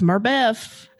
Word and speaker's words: Murbeth: My handle is, you spell it XMURBEF, which if you say it fast is Murbeth.